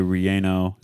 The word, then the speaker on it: relleno: Jalisco Special has enchilada, tamale, chalupa, chile, relleno.